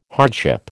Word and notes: hardship: American English

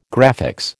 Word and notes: graphics: American English

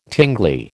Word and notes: tingly: American English

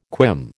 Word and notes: quim: American English

